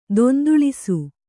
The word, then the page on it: ದೊಂದುಳಿಸು: donduḷisu